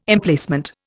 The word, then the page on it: Emplacement: Indian